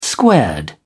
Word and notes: squared: British English

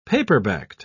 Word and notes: paperbacked: British English

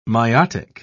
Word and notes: myotic: British English

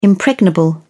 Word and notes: impregnable: British English

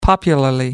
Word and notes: popularly: British English

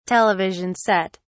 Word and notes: television set: British English

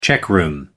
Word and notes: checkroom: American English

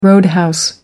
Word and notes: roadhouse: American English

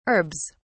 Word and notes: herbs: British English

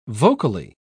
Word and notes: vocally: British English

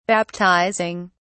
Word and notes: baptizing: British English

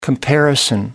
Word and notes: comparison: American English

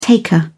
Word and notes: taker: British English

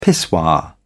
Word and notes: pissoir: British English

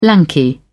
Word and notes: lanky: British English

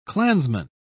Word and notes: klansman: British English

